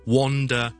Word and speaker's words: wander: 同埋 wander 系读 wander，周围游魂野鬼咁游魂咧，就叫做 wander，周围游啊，周围碌，周围行咁就 wander。咁、那个发音系有啲奇怪嘅，因为虽然我串字 wan，但系我系读 o 音嘅，所以 wander around the neighborhood 喺呢个附近嘅地方周围游咧，叫 wander。